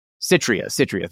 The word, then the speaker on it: Citria: Like, I read a lot of the thing from Critia or whatever you want to call it, however it's pronounced. Citria, thank you.